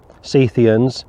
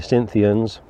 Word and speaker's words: Scythians: You've also got Scythians, mentioned over in Colossians chapter 3. Schofield suggests that the Scythians, or Scythians as I should correctly pronounce it, could well be in reference to Russia.